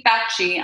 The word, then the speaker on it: Fauci: is it Fauci? Is that how you say it? – Yeah, it's Anthony Fauci. – Dr. Anthony Fauci on an SNL skit, which was awesome.